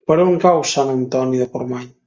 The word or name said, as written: Antoni